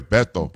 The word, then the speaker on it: Beto: You're still pronouncing it like the Spanish way. Beto.